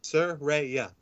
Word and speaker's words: Saraya: Saraya. – Thank you. See, I'm from Kentucky. We don't Saraya. Talk right down here. So Saraya.